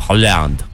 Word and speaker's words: Haaland: Segundo a nossa amiga, Liane Braga, que estudou na Noruega, se pronuncia Haaland. – O melhor atacante do mundo.